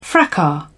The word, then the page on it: fracas: British English